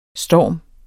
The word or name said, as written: storm